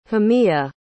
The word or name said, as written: hamia